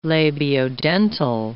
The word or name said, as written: labiodental